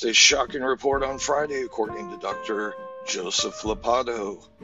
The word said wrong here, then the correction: Ladapo: Did I say that right? – Ladapo. Oh, see, that dyslexia kicked in. Take two. Florida Surgeon General released a shocking report on Friday, according to Dr. Joseph Ladapo.